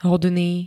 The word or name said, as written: hodný